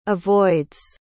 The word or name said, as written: avoids